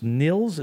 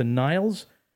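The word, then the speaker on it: Nilles: Her name is Anika, I believe, because it's double L's, it's Nils as opposed to Niles.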